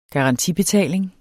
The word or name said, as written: garantibetaling